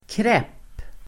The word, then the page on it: kräpp: [krep:]